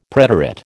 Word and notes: preterite: American English

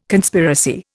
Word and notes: Conspiracy: Indian

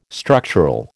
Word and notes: structural: American English

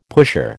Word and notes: pusher: American English

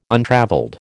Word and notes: untravelled: American English

